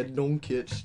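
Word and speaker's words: Dončić: Yeah, Doncic.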